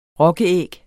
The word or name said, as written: rokkeæg